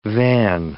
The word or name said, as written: van